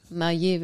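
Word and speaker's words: Mayer: Der Franzose da, der, der Franzose, der Meyer heißt, Maillet wird er, glaube ich, ausgesprochen.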